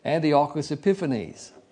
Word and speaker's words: Antiochus Epiphanes: We can identify the little horn from history as Antiochus, and I know Simeon pronounced it differently, but I'll give it to you as I've always heard it, Antiochus Epiphanes, who was alive and ruled from 178 BC to 164 BC.